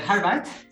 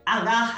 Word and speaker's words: Harvard: Ah, j'ai eu du mal à prononcer Harvard à la française. Hein. – C'est pas la seule. Nous aussi, ça nous pose beaucoup de problèmes. Donc, non, au contraire, c'est bien dit à la française.